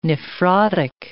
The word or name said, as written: nephrotic